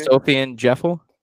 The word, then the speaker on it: Sofiane Djeffal: Sofiane Djeffal.